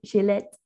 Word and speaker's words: Gillette: Today I'm so excited we're talking to Michelle Gillette, and I actually pronounced it before more French, right? Gillette.